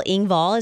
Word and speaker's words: Engvall: Bill Engvall is 60.